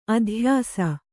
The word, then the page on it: ಅಧ್ಯಾಸ: Kannada; adhyāsa